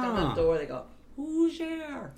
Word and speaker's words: Hoosier: Is that, did I pronounce that properly? Hoosier. – You did it perfectly, because you know what it means?